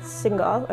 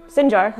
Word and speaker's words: Sinjar: It was a very coordinated and choreographed attack, came from three different directions, and uh, they attacked a place called Shingal, or Sinjar, that's how you say it in English, Sinjar, in northern Iraq, and the Kurdish forces that were protecting the area couldn't really hold out against ISIS.